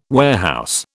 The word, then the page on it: warehouse: British English